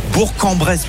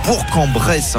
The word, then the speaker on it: Bourg-en-Bresse: On va se diriger tranquillement vers Bourg-en-Bresse parce qu'on dit Bourg-en-Bresse. Hein, on prononce bien le G.